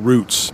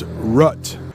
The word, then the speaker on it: route: You can usually go faster on interstates than you can on routes. But that word route in English, sometimes you will hear that pronounced as rut. We have two different pronunciations in the United States for that type of road.